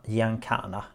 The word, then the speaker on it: Giancana: Eh, nu ska jag se hur man uttalar det här. Gia... Giancana.